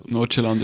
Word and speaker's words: Nordsjælland: Ist 2016 hat er bei, tut mir leid, ich kann es wirklich nicht aussprechen, Nordsjælland. – Nordsjælland ist aber richtig.